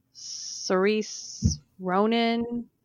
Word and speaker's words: Saoirse Ronan: I know there's a new movie that has like Margot Robbie and Saoirse Ronan is how you say it in it.